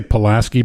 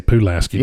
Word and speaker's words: Pulaski: I say Pulaski, but they say Pulaski down there. – Yeah, that's right. You got to pronounce it properly.